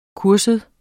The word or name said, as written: kursus